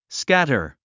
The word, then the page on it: scatter: skǽtə r; スキャタァ